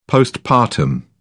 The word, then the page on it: postpartum: British English